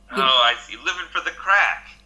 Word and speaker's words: craic: Okay, yeah, so crack, when we say it, is spelled C-R-A-I-C. It's the shortened version of the Irish word crackina, which means fun.